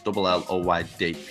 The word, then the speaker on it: Lloyd: It's LLOYD.